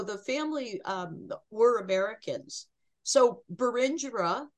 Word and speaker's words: Berengera: Berengera Caswell, correct? – Close, Berengera.